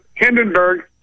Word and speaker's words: Hindenburg: Hindenburg, sorry. Rhymes with Vandenberg.